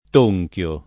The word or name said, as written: tonchio